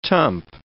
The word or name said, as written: tump